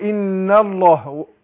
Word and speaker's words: الله: يعني بنطق بعد النون ايه لام ان يبقى الهمزه فين، لكن لو نطقت الله اقول الله يبقى اثبتها اثبت الهمزه فين في اول الايه الكلام اقول الله، لكن في وسط الكلام اقول ان الله سقط.